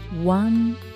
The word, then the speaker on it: one: one day one の最初の音は発音記号 W ですね口をぐっと丸め